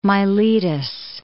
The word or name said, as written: Miletus